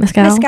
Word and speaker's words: Mescal: But anyway, she posted or she reposted, I think it was on St. Patty's Day, a picture of Paul Mescal. I don't know how to say his last name. – Mescal.